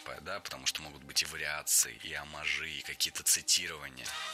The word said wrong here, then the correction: оммаж: Оммажи – это неверно. Слово «оммаж» имеет ударение на втором слоге, и при склонении ударение остается на том же месте. Оммажи, оммажей, оммажами.